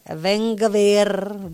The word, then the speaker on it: Ben Gvir: El ministro de Seguridad Nacional de Israel, que lleva por nombre Ben Gvir, así es, Ben Gvir, yo digo que así se pronuncia, catalogó directamente este suceso como un intento de atentado terrorista.